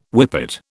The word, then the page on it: whippet: British English